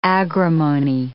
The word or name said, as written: agrimony